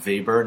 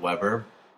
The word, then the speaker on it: Weber: Next is Weber's Law, pronounced Weber, not Weber.